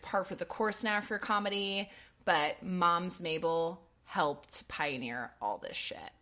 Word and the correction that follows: Mabley: Mabley. I'm sorry, Mabley. I'm so sorry. Jackie Moms Mabley. I'm saying her name wrong. I dropped the Y. M-A-B-L-E-Y.